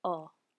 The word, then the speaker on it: ho: So just a hint if you're reading along, the H is silent. I have. Io ho.